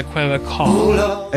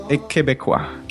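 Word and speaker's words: Québécois: And then someone from Quebec is a Quebecois. – A Quebecois. – Quebecois. – That's it.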